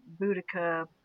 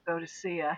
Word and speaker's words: Boudica: from Boudica, Boadicea, however you choose to pronounce it, through Elizabeth the First, through Queen Victoria, through the current Queen Elizabeth, they're used to female leaders.